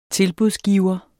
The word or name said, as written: tilbudsgiver